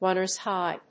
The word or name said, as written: runner'shigh